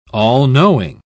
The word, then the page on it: all-knowing: British English